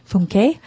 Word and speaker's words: Funke: That's how you say her name. Funke.